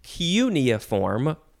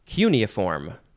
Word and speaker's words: cuneiform: Next is cuneiform or cuneiform. I said those the same way. Cuneiform or cuneiform. You get emphasize the first or the second syllable.